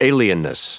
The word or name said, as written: alienness